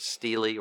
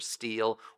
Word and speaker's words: Stele: That's a very important thing for you archaeologists, the Merneptah Stele or Steele or Stella. It's pronounced all three different ways.